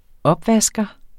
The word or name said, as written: opvasker